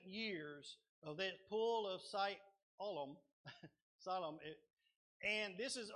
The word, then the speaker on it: Siloam: It's not Siloam, it's Siloam. Siloam.